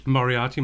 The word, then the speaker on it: Moriarty: Moriarty, my apologies.